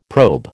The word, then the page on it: probe: American English